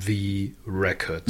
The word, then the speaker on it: the record: The Record. The. – The, Rare. eigentlich The, Record. The, Record. The, the Album, The aber, Record. Also Betonung ist schon auf The.